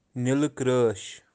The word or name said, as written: nilikreash